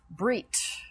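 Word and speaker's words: Brit (ברית): Brit. B apostrophe R-I-T is probably the best way to say it, spell it, transliterated, brit.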